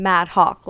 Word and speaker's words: Madhok: It's Aneesha with two E's, so you smile when you say my name, and Mad Hawk, like a mad bird, but just H-O-K.